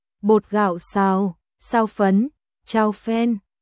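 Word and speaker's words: chao phen (炒粉): Xào, đọc nhướn lưỡi lên. Cháu đọc thành âm thẳng lưỡi. Kiều Thần nói, ngủ với phen, trong tiếng Trung là thảo phấn, cao phen.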